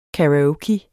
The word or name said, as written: karaoke